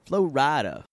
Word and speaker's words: Flo Rida: Artist known as Flo Rida was not pronounced Florida.